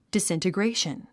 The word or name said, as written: disintegration